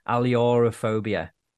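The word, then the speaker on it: ailurophobia: Okay, so that's how to pronounce it. Aliorophobia.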